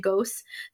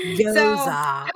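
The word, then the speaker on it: Gose: I've always pronounced it Ghost. So now I learned something new today. But reading that makes me very worried at how sour it's going to be. – Goza.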